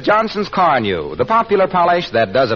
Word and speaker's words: Carnu: If I were you, I'd buy a package of Johnson's Car New and clean and polish my car this week. You won't regret it. It's spelled C-A-R-N-U, Johnson's Car New.